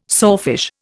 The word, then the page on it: sawfish: Indian